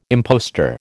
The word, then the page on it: impostor: American English